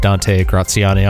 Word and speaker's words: Graziani: Graziani, just phonetically, you did right.